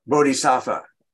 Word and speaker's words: bodhisattva: how do you say, the bod? – Bodhisattva. – It's bodhisattva, thank you.